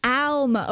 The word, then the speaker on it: Alma: Alma? – Alma. Or an owl sound, yeah. – Alma, okay.